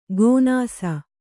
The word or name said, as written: ಗೋನಾಸ